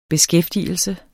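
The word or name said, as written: beskæftigelse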